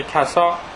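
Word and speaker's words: کساء: حالا یه توضیح راجع به حدیث کسای مفصل اینجا هست. این کسا بگید دیگه، کسا نیست، کسا، حدیث کساست تلفظ صحیحش.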